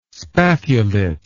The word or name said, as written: spathulate